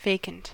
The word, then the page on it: vacant: American English